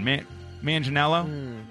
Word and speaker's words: Manganiello: Manganiello? – I think you're there.